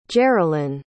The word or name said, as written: gerrilyn